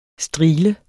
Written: strigle

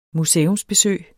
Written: museumsbesøg

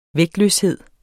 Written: vægtløshed

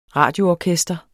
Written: radioorkester

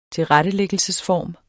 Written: tilrettelæggelsesform

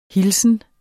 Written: hilsen

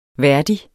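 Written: værdig

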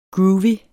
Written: groovy

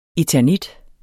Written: eternit

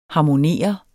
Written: harmonere

